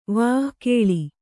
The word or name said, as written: ವಕೀಲಿ